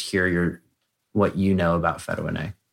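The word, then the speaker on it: Fetuin-A: Let's talk about Fetoin A, because I was reading your cardiac manifesto, to pronounce it in somewhat of a French way. But Fetoin A,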